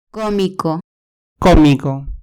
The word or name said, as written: comico